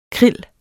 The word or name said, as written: krill